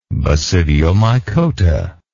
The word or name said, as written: basidiomycota